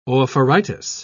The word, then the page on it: oophoritis: British English